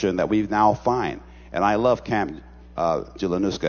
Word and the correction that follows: Junaluska: Am I pronouncing it correctly? Julianuska? Did I say it right? Huh? Janaluska? My brother, I'm going to hug you afterwards for getting me on course.